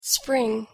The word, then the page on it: spring: English